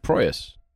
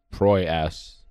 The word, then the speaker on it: Proyas: That's Proyas. Not Proy-ass.